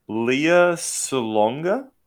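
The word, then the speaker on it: Lea Salonga: Leah Salonga.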